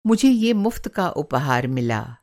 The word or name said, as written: मुफ़्त का